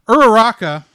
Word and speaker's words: Uraraka: Araka, or er, I'm gonna mis- say her name wrong. Akak, Uraraka was played by Lucy Christian.